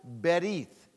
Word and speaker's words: Berith: Just one Bible word, and the word is a Hebrew word, and it's pronounced Berith, B-E-R-I-T-H.